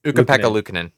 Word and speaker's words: Ukko-Pekka Luukkonen: Ukko-Pekka Luukkonen.